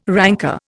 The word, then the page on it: ranker: Indian